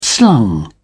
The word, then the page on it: slung: British English